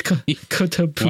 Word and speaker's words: Kotobukiya: It's Kotobukiya.